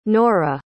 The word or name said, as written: nora